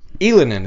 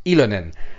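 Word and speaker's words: Ylönen: Elinen is how you pronounce it, by the way. I actually had to clear that with my buddy Pat Bexel. It's Elinen.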